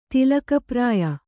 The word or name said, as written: ತಿಲಕಪ್ರಾಯ